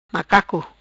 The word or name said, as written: Macaco